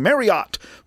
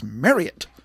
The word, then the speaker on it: Marriott: And, for years we've all been saying Marriott. Well, it's not. It's Marriott. Rhymes with chariot.